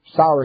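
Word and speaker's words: Sauer: If there's any new-timers, sour is the best of the works, isn't it? Well, to me, it's kind of like his name. It's S-A-U-E-R, but it's kind of S-O-W-E-R. Sour.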